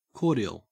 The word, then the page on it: cordial: Australian English